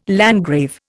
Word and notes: landgrave: Indian

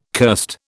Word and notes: curst: British English

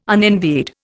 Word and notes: unenvied: Indian

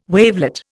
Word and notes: wavelet: Indian

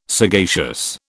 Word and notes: sagacious: British English

